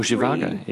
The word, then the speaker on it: Zhivago: Or Zhivago, even. – Dr. Zhivago, is that how you say it? Oh, yeah. It is. I've been putting an extra I in there for years.